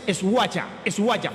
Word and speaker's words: Schweiger: La pronunciación en alemán es Schweiger.